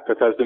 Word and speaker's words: Bethesda: Bethesda. – Yeah, Bethesda, Maryland, mm.